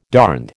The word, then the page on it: darned: American English